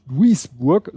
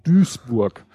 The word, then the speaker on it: Duisburg: Er schrieb, ich glaube, und jetzt spreche ich es mal falsch, also wie auch immer, Duisburg spricht sich nicht Duisburg, sondern Duisburg.